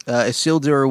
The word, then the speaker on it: Isildur: Am I saying that incorrectly Isildur. – For you? Isildur. Isildur. Which, of course, is a Lord of the Rings reference, right? – Mm-hmm. Yeah. – Tell me all about that. – Isildur was the, was the prince of the basically men and elves allied to fight Sauron, the last alliance coming together.